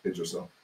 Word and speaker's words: Yggdrasil: Yggdrasil. – Yggdrasil.